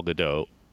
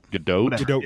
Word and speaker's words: Gadot: YouTube video of her pronouncing her own name, and so it has to do with her being it's Israeli, Gadot.